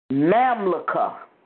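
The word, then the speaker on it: Mamluka (ממלכה): Then it's Mamluka.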